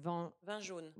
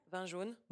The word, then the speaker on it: vin jaune: Vin jaune. – Vin jaune. Am I saying it right? – Vin jaune.